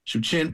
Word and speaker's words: Wuichin: That's right, 4311 Hubbard Street, Shuchin Park. It's spelled W-U-I-C-H-I-N.